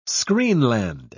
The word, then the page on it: screenland: British English